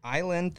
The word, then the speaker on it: Islay: Island that this is from, it's spelled I-S-L-A-Y. – I-S-L-A? – Isla. – Isla? – Isla.